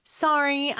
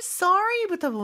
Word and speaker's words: sorry: Sorry, I missed your message이죠. 일단 sorry부터 보면요. 미국에서는 sorry 이렇게 mm-hmm. 발음이 나고요. 영국에서는 sorry 약간 오 발음이 더 가까운 것 같아요.